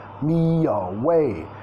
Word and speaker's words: away: Vamos a vincular y away, tenemos la primera sílaba como sonido schwa, me, me, me away.